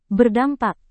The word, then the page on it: berdampak: Indonesian